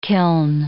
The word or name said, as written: kiln